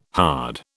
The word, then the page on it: hard: British English